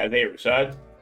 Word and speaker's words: Isaiah Rashad: No, Isaiah, how do you say his name? Isaiah Rahad. – Sylvia? He shall look it up. Yeah, Isaiah Rashad.